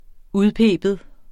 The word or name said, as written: udpibe